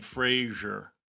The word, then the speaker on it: Fraser: By the way, we've been saying it wrong. I'm sorry to interrupt you, but I got yelled at on the internet. It's Fraser. We've both been saying Fraser. – It's Fraser? – Fraser, like Fraser. – Like Laser. – Like Laser. Fraser.